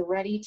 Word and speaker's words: RHETI: So if you want to go take a test, which is usually what most people do next, I always recommend what's called the READY test. It's spelled R-H-E-T-I, and that stands for the Rizzo-Hudson Enneagram Type Indicator.